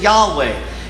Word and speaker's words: Yahweh: Now we pronounce it Yahweh.